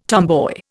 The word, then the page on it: tomboy: Indian